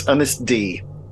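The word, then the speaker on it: Ynys Ddu: So the U in Welsh, as in Cymru, is pronounced E. So it's Innisdu.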